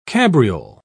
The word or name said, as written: cabriole